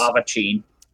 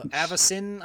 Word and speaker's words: Avacyn: Uh, I'm still gonna pronounce is... it Avachin. – Nice. What? uh, Avacyn, I think, is the official pronunciation of that, or some something along those lines.